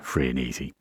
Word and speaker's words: Frenesi: And that, of course, was Artie Shaw with Free and Easy. Yes, it's spelt Frenesi, F-R-E-N-E-S-I, but it's pronounced Free and Easy.